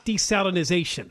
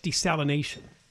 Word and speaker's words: desalination: And it's not desalinization. I hear people pronounce it that way. It's just desalination. There's no Z in there.